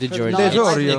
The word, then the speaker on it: DiGiorgio: Yeah, Steve DiGiorgio.